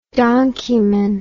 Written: donkeyman

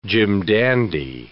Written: jim-dandy

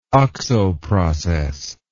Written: oxo process

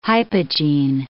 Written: hypogene

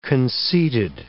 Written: conceited

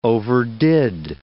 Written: overdid

